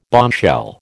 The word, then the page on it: bombshell: American English